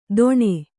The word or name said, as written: ಧೊಣೆ